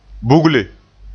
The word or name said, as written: bugle